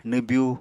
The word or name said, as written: nwbyu